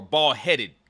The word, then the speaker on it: ball-headed: You spell it ball-headed, but you pronounce it ball-head. These ball-head games. – Oh, we have dissent already. – So the ED is silent.